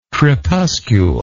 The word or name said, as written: crepuscule